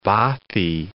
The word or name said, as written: bothy